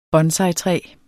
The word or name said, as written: bonsaitræ